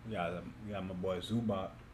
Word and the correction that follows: Zubac: You mean Zubats? – Zubac. How you pronounce it? – Zubac? It's definitely Zubats.